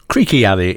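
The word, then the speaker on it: Creeque Alley: Before Prince, you heard the well-known autobiographical piece by the Mamas and Papas, Creek Alley, pronounced Creaky Alley in real life.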